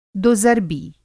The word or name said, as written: दोज़र्बी